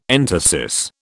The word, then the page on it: entasis: British English